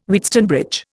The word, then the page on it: Wheatstone bridge: Indian